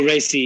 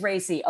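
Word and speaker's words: Raci: Paul Racy, yeah. – It is Racy, okay.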